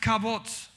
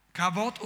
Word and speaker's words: kavod: Das Wort Herrlichkeit im Hebräischen, eines der Worte, heißt Kabot. Kabot oder Kabot. Dieses Wort heißt Wichtigkeit, Gewicht, Schwere. Ja? Da schreibt man K-A-V-O-D.